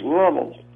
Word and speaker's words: Louisville: But in Louisville, it's pronounced Lovell.